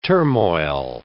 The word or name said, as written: turmoil